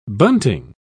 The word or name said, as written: bunting